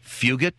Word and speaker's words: Fugit: I swear to God, F-U-G-I-T. I ain't even going to try to pronounce. Fugit, is that okay, or am I now fired? Ah, Fugit. Like a J.